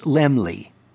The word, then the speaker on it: Laemmle: But the correct way of spelling the word, or pronouncing the word, is Laemmle. I use a memory aid. You know me and my memory aids. Lem, L-E-M, like lemon, and Lee, L-E-E, like Annabelle Lee, Laemmle.